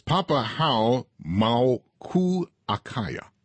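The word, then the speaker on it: Papahānaumokuākea: The name of the monument is Papahau Maukuakaya.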